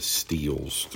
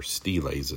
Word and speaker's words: stele: Many famous steles throughout, or steles as some pronounce it, throughout history.